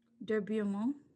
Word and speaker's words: Beaumont: Say how you would say it. – De Beaumont. – De Beaumont. Beaumont. – Beaumont. – But there's no it's R. – Fine. That's just how we pronounce it.